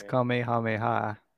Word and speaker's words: Kamehameha: It's Kamehameha, like with the K at the front.